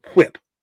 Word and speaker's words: whip: You gotta put emphasis on the WH. Whip.